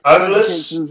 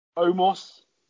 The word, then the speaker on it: Omos: Amos. I never know how to pronounce his name. – Oh, Amos, the Raw tag team match. – Omos. – Xavier Woods. Omos.